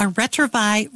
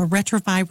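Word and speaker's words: Retrouvaille: Perhaps you could recommend that they go on a marriage encounter weekend, or if things are even more desperate, a retrovi retreat. Now, it's a French word. Let me spell it for you. R-E-T-R-O-U-V-A-I-L-L-E. A retrovi retreat.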